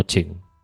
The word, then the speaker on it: Qing: Pues aunque parece escrito como King, eh, al final he escuchado otros podcasts así y pronuncian como Ching.